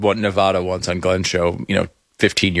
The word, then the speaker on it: Nevada: And October 27th through November 1st, Las Vegas, Nevada, as they pronounce it. – It is. It's Nevada.